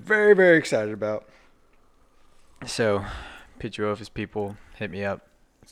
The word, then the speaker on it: Pituophis: Yeah, I'm I'm all about Pituophis now. I think I pronounced that right. – Mm-hmm. – Did I get that one right? Pituophis.